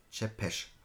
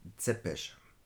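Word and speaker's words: Țepeș: Denken Sie bitte daran, es wird Chepesh ausgesprochen. Im Original steht es äh, auch so ähnlich drin. Remember, it is pronounced Zepesh.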